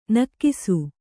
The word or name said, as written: ನಿಕಸ